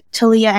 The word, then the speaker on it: Taliyah: and it's just at Taliyah Engineering. My name is spelled T-A-L-I-Y-A-H Engineering.